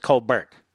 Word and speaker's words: Colbert: Stephen Colbert has a brother who's a lawyer who pronounces his last name Colbert, just so you know. He's a Washington lawyer. So Stephen Colbert does that, and his brother says Colbert.